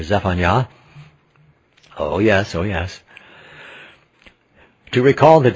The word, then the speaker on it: Zephaniah: Zephaniah putting the accent on the last syllable, where it is Y-A-H. So we think of Yahweh first, even though it's the end of the name of Zephaniah.